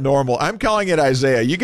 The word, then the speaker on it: Isaias: Isaias is technically pronounced Isaias, which is a Spanish form of the name Isaiah.